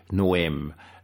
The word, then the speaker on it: Noem: Und in dem South Dakota ist es eine Dame namens Kristi Noem. Spricht man anscheinend wirklich so aus, Noem geschrieben und wird anscheinend von den Amerikanern auch so ausgesprochen.